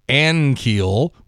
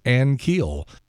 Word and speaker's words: Ankiel: He's a great guy and everything, but he just can't hear the difference between Ankiel, the way he says it, and the correct pronunciation, which is Ankiel.